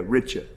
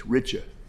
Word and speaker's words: Wrycza: How did you pronounce that name? – Pardon me? – What's her name? – Ms. Richa? – Yeah, Richa. That's the W-R-Y-C-Z-A. – Yes, that's correct. – That's Richa.